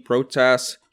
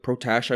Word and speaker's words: Protas: I like Alexi Protas. Protash, I believe you pronounce it.